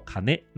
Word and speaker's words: 金: 当然，在这其中呢，还有另外一层意思，就是金字在日文之中的念作卡内。